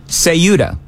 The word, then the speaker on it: Ceuta: He is saying it's TH. I really don't care either way, but what is the official pronunciation from the newsroom? – Ceuta. – Ceuta. Okay, so Joan's right. And yeah, if you're speaking Latin American Spanish, by all means.